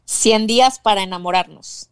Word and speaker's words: 100 Días para Enamorarnos: 100 Días para Enamorarnos.